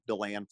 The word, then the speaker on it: DeLand: Uh, there was a high school, and I, I think it was Delane, Florida, but I probably probably got that wrong um, Deland, Florida. I probably butcher that.